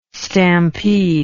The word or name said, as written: stampede